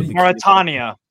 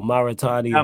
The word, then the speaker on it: Mauritania: Mauritania. – There we go, Mauritania. – Um, them boys go. there. – Mauritania.